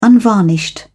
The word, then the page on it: unvarnished: British English